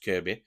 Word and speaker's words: Kirkby: it's spelt Kirkby, but it's pronounced Kirby, so that is the SK, and then there's also Upton, which is the smallest of the three, right on the very end.